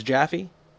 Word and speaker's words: Jaffee: His name is Seth Jaffee. Seth, did I say that right? – Yes. – Is it, it's Jaffee? – Yeah.